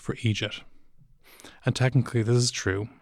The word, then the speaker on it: eejit: My view is that, yes, while we have Amadáns and, and so forth, and many other words for fools, eejit is specifically a formation of idiot phoneticized the way we pronounce it here, with that, that soft G, that kind of the eejit.